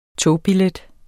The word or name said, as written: togbillet